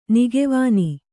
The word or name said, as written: ನಗೆವೆಣ್ಣು